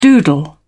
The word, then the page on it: doodle: British English